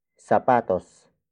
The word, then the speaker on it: sapatos: Mga is spelled as M-G-A. It is the plural marker for the next word, which is sapatos. Sapatos. Sapatos. Sapatos is spelled as S-A-P-A-T-O-S.